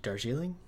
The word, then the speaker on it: Darjeeling: The Darjeeling Limited, is it Darjeeling? Darjeeling? – Hey, buddy, I think you got it. – Okay, I think you got it right there.